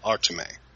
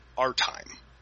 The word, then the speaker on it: Artime: I'm reminded of our listener Joshua Cawley when he corrected my pronunciation of Manuel Artime. I called him Artime, but the proper way to say his name was Artime.